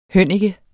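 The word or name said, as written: hønnike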